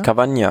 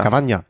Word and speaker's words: Cavagna: Remy Cava, wie spricht man das? Cava, Cavagna? Cavagna. Cavagna. Ja.